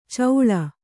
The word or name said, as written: ಚೌಳ